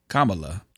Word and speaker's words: Kamala: They got a chance to let him escort Kamala. I always mess her damn name up. As a reference, she says the way you say her name is like comma, and then la, Kamala. Kamala.